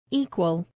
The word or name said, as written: equal